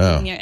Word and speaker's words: Lumier: It's not really spelled like you would think. Lumiere. L-U-M-I-E-R.